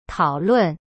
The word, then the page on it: 讨论: tao-lun